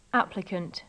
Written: applicant

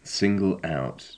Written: single out

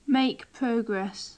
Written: make progress

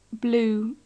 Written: blew

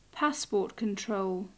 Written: passport control

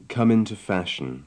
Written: come into fashion

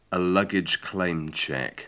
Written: a luggage claim-check

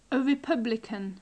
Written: a republican

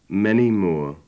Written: many more

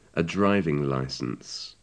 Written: a driving licence

